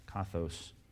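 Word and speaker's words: kathos (καθώς): Let's start in verse 12, kathos. K-A-T-H-O, long O-S, kathos, kappa, alpha, theta, omega, sigma, for you fraternity boys.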